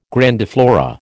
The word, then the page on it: grandiflora: American English